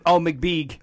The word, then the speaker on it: Mbege: It's spelled M-B-E-G-E. – McBeeg. Yep. Got it. – That's right.